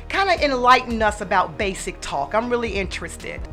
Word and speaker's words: Baysick Talk: Let me know if I'm pronouncing this correctly. Basic Talk. – That is absolutely correct.